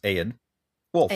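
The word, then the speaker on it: Eodwulf: It's like the word aid, like a band aid, but then make it two syllables. Aid. Wolf.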